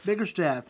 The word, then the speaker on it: Bickerstaff: Bickerstaff, how do you say that? – Bickerstaff is the head coach.